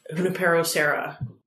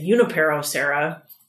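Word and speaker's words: Junípero Serra: Yeah, like the major thoroughfare on the west side of town, Junipero Serra. But I hear people say Junipero Serra. – So which is it? – Well, if you're going by the Spanish pronunciation, the J is pronounced as an H.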